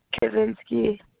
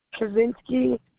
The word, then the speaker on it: Kuczynski: Kaczynski. – Spell that for me. – Kaczynski, KUCZYNSKI.